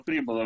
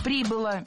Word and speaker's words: прибыла: Когда совершенно безумно ее мама прибыла в Голливуд, зачем-то где-то устроилась какой-то монтажницей, вышла, была замужем, родила дочку. – Прибыла, неверно, правильно, прибыла. Ударение фиксируем все там же, на окончании.